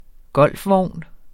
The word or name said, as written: golfvogn